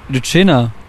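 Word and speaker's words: Lützschena: Ich bin kein Sachse, deswegen kann ich das nicht richtig aussprechen. – Wie spricht man wie das spricht aus? Man die Sternburg Brauerei aus? – Lütschener. – Ja, das habe ich auch richtig gemacht.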